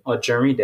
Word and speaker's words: Ojerinde: It's spelled R-A-S-H-E-E-D. And then my last name, Ojerinde Date, which is O-J-E-R-I-N-D-E.